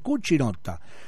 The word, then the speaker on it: Cucinotta: Así que bueno, mirá a esta actriz, 1968, nace María Gracia Cucinotta, te lo digo bien en italiano, Cucinotta.